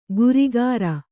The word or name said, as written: ಗುರಿಗಾರ